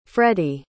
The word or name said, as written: freddy